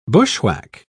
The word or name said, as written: bushwhack